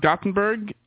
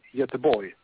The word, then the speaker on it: Göteborg: I was in Gothenburg. That's not how you say it, I know, right? How do you say it? – Yeah, Gothenburg. Well, we say Göteborg.